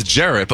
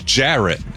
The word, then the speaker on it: Jarrett: Ferret does rhyme with Jarrett, but my name is Jarrett. Jarrett.